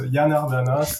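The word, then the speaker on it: Janardana: Parce que théoriquement, c'est comme ça que ça se prononce. Yanardana,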